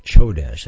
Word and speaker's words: Chodesh: Kodesh, Strong's Concordance, and this is not to be confused, Kodesh, with Q-O-D-E-S-H, being separate or pure. But this is Chodesh, as I'll pronounce that for you.